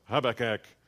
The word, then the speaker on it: Habakkuk: Is it Habakkuk? Hab- Habakkuk. It's Habakkuk. I'm, I'm, giving you the defining defi- or the defining pronunciation here.